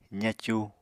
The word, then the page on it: zacu: apatani